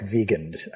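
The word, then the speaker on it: Viigand: And the one and only thing that this opponent, which I'm trying to, his name is Leonid Villigand. – I think they pronounce it like Vigand, I think.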